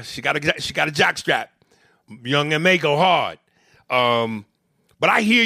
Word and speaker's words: Young M.A: Young M.A. And for a long time, I thought her name was Young Ma, but I think it's pronounced Young M.A.